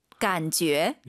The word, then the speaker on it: 感觉: 感觉. – 여러분, J-U 이 발음은 절대 간죄, 주의 아닙니다. 너의 죄입니다. 간죄. 죄. 너의 죄를 사하노라. 죄를 기억하세요. 이건 진짜 한글로 써도 괜찮을 것 같아요.